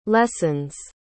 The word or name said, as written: lessons